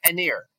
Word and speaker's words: Aniar: And and so with with the other restaurants like Anyar, it, that's how we pronounce it, Anyar, isn't it? Or Anyar? Oh, Aniar. Aniar, sorry, sorry, Aniar.